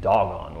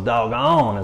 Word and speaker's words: Dagon: It's actually named Dagon, which I think is just hilarious, right? You know? And so Dagon is how you say it.